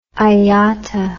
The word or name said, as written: iata